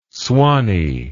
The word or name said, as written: swanee